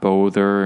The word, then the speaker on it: bother: It's spelled with a B-O, but it's not bother or anything like that. If you know the word father, it's pronounced just like father. Even though father has an A, this is an O. English is a weird language. so this is bother.